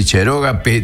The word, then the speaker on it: cherógape: Ahí va el acento en la O y en el P, sino cheroga P.